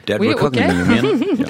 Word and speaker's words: Dead Reckoning: Dead Reckoning. – Dead Reckoning.